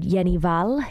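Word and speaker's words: Jenny Hval: It's a song by a Norwegian artist called Jenny Val. Jenny Haval is how it's spelt.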